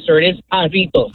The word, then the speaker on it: Jarritos: Pronounced Jar Toast. – What happened? – Pronounced Jar Toast. – Well, I guess if you're a dumbass, it is. But no, it's Hispanic, so it's pronounced Jarritos.